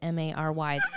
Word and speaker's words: Mary: Most young people under 30, they don't even spell it Mary. I swear to God, I go to Starbucks, it's Merry, like Merry Christmas.